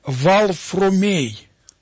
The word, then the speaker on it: Варфоломей: Оно произносилось не как Варфоломей, а произносилось как Валфромей. Валфромей. Вот. Так наши предки произносили это имя, так к нему обращался его отец Кирилл, мать его Мария.